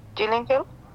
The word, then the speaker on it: Gillingham: Gillingham.